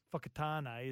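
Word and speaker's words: Whakatāne: Fakatane is spelt WH, for example. – Correct. – A lot of people that don't know how to pronounce Fakatane. – Yeah. Yep. – They would, yeah, so. – It's like Phuket. – Yes. – It's not Phuket.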